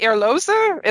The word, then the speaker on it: Erlöser: The Erlöser is my, your German's better than mine. Am I saying that right? – Yeah.